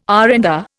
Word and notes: R and R: Indian

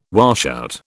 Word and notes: washout: British English

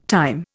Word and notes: time: Indian

